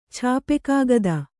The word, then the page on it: ಛಾಪೆಕಾಗದ: chāpe kāgada